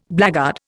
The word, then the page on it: blackguard: Indian